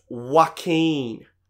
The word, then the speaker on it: Joaquin: I said, excuse me, it's pronounced Joaquin.